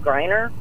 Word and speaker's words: Griner: Yes, the reason why I'm calling today is because of that Brittany Griner.